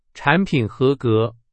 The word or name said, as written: 合格